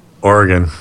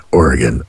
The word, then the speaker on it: Oregon: Oregon. – Yeah! – Woo, you did it! – I have it said correctly in my brain. Oregon. – Yes, but you don't want to say Oregon.